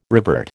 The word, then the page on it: revert: American English